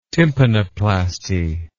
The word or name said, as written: tympanoplasty